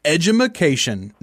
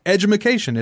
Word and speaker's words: Edumaction: This week on Pod, we're going to be talking about Edumaction. No, I didn't mispronounce that. Edumaction.